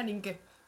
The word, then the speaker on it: Nienke: ¿Te dijo Nienke o Ninke? – No me acuerdo. Yo estoy acostumbrada a que me digan Nienke, que ya me da igual. – Es que estoy a punto de ponerle... Se pronuncia Ninke.